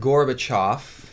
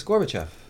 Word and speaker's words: Gorbachev: I also heard Gorbachev. – Yeah. – Which makes no sense. It's Gorbachev.